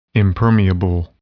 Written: impermeable

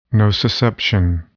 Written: nociception

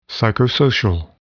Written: psychosocial

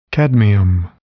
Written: cadmium

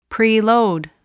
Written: preload

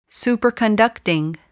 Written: superconducting

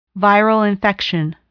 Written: viral infection

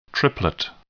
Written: triplet

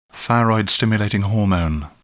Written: thyroid stimulating hormone

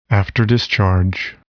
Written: afterdischarge

